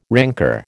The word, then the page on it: rancour: American English